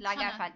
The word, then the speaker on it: Lagerfeld: Lagerfeld diye okunuyor.